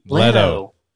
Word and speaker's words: Leto: How do you say it? – Leto. – Leto. – L-E-T-O. Leto. – Just how it's spelled. – L-E-T-S-G-O.